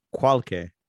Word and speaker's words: Quolkeh: And then she meets a couple of people, Tartarus and Kyoke. – Kyoke? – Q-U-O-L-K-E-H. – Spell that again. Q? – U-O-L-K-E-H. – Qualke. – Qualke.